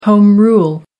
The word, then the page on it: home rule: American English